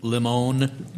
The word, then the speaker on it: Limon: All right, so the third one we're doing is a Bacardi Limon Lemonade. Is L-I-M-O-N pronounced limon, you think? – It is.